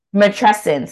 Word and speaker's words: matrescence: Matrescence. – So like, I'm going to prepare for this matrescence because I've been hearing recently about like, once I became pregnant, the fourth trimester, right? So is matrescence is it once you have the baby, you go through matrescence? – Yes.